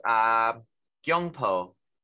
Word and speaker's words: Gyeongpo: I'll quickly do my one in Korea is Gyeongpo. I think I pronounced that okay. Tokjun. – Yep. Korean approved.